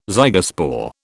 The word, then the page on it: zygospore: British English